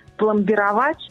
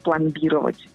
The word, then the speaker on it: пломбировать: Очень часто люди говорят вместо нормативного «пломбировать», «пломбировать», что неверно, а правильно говорить «закупоривать», а не «закупоривать».